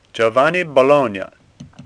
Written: Bologna

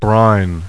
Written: BRINE

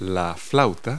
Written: flute

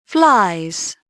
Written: flies

